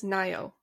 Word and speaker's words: Ngaio: It's spelt N-G-A-I-O. – Mm-hmm. – And I should have looked it up, and now I have. And the internet says Nayo.